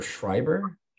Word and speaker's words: Schreiber: It says pronunciation like fiber. Oh, Schreiber? – Schreiber.